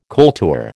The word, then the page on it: kultur: American English